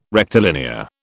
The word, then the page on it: rectilinear: British English